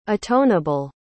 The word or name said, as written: atoneable